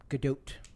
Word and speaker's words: Gadot: You pronounce the T. – Do you? – Gadot.